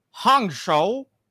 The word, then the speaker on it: Hangzhou: pretty sure that in, in in Korean the H is pronounced like a J. – Yes. So you have Hangzhou, is a Zhejiang province in China.